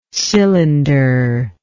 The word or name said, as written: cylinderlike